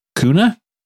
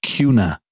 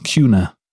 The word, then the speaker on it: Kuna: K-U-N-A, Idaho. That's how they spell the town. K-U-N-A. And anytime that you call it Cuna, which is kind of like it's spelled, all the Idahoans are like, hold up. It's just this little town. And they're like, hold up. It's Cuna. Like a cue ball, Cuna.